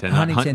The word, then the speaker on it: Huntington: You pronounce it Huntington, not Huntington. – Yeah, the T's kind of like silent.